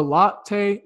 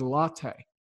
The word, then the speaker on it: Lotte: I was saying lot. It is spelled L-O-T-T-E, but it is latte. It just kind of, you know, sounds like a coffee drink to me.